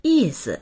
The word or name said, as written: yi4si